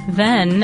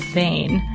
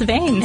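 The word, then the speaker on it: Hven: this made sense when we learned that the town in question isn't pronounced then, as in your podcast, it's pronounced Vane.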